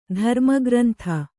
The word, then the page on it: ಧರ್ಮಗ್ರಂಥ: kannada